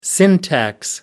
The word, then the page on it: syntax: American English